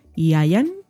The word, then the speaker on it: Iain: Está creada por un señor de apellido Holland y un nombre que no sé realmente cómo se pronuncia. Se escribe I-A-I-N. Iayan, Iain. Si lo decimos aquí.